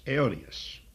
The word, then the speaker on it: aionios: It is the adjective which is spelled A-I-O-N-I-O-S, aeonios.